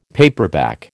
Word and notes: paperback: American English

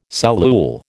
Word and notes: cellule: American English

